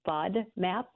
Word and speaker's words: FODMAP: People pronounce it as FODMAP. – Yeah. – Well, that's it's an acronym.